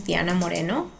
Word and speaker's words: Diana Moreno: My name is Diana Moreno.